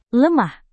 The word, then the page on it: lemah: indonesian